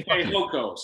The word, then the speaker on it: Fehoko: I believe it's Foku. – Fokus. Yeah, I almost said fuck you. – Fehoko. Sorry. Simi Fehoko.